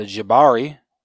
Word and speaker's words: Jabari: Namor vows to return in a week with his full army, and the citizens of Wakanda relocate to the Jabari.